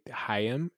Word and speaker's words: Haim: That's how they, Haim is how that's pronounced. I always thought it was Haim. – No, it's like a Jewish Haim, like the Haim.